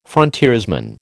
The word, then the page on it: frontiersman: American English